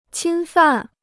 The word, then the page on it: 侵犯: qīn fàn